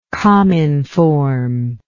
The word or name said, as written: cominformist